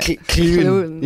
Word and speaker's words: Llewyn: Well, you're lucky you don't have any, like, sort of Welsh people calling it Llewyn. Yeah. One guy did. I thought that was pretty cool. – Wow, yeah. Obviously, that's how you would pronounce it in Welsh.